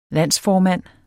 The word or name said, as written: landsformand